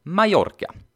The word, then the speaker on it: Mallorca: Mallorca stavas med dubbel L och ska därför uttalas Mallorca.